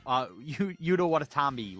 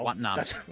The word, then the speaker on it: Watanabe: Yuta Watanabe. I always get his name wrong. – No. Watanabe.